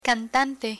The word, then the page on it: cantante: Spanish